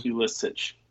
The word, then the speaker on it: Pulisic: Pulisic, I think is how you say it. Is that right? – Pulisic.